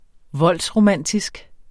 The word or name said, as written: voldsromantisk